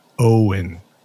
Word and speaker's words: Eoin: Now in Ireland, the name Owen is spelled E-O-I-N. Owen.